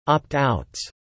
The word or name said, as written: opt-outs